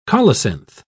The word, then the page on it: colocynth: British English